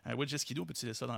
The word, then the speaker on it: Wojcicki: Et en fait, c'est reconnu, la dirigeante Susan Wojcicki, et c'est comme ça qu'on prononce son nom, parce que j'ai regardé juste ça. – T'es bon. Tout le monde répète, Wojcicki. Wojcicki.